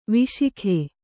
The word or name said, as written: ವಿಶಾಖ